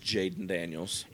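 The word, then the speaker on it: Jayden Daniels: Sir, it is Jaden Daniels. – It is. Jalen Daniels is for the Jayhawks. Oh, my God. – I never want to hear you fuss at me for mispronouncing Jalen Daniels as Jaden Daniels.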